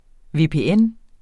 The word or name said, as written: VPN